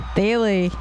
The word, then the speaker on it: Thiele: Thiele. – Thiele. – We now know how it's pronounced.